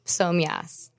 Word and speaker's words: Psomias: Psomyas.